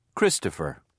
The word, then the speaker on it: Christopher: H-A-N-N-A-H. – 6. – What's your name? – Christopher. – How do you spell it? – C-H-R-I-S-T-O-P-H-E-R.